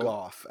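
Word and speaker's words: Gough: Michael Goh was, is that how you say his name? I'm going to assume it's like Van Gogh. – Goff, actually, but I could be wrong.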